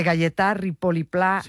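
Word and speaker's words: Gaietà Ripoll i Pla: a ver si lo digo bien, de Gayetà Ripoll i Pla, un mestre lliure pensador que tenía la seua escola a l'Horta de Russafa. – Perfecto. – ¿Lo he dicho bien? – Sí, vale. Y se ha entendido perfectamente.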